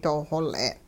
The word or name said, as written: doo-xol'e'n